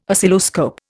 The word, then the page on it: Oscilloscope: Indian